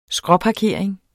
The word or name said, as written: skråparkering